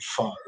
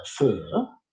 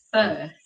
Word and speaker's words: pho: So I come in for a bowl of pho, which I understand is very poorly pronounced. Fur. – Yes, correct. Fur. – Fur.